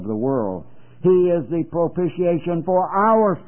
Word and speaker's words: propitiation: I can remember, this seems kind of humorous, but I think in pronouncing this word so often, I've heard people say, someone would say, proposition for propitiation. And they had to be corrected, and that's not the way you pronounce that word, because that word has the meaning of sacrifice or mercy seat.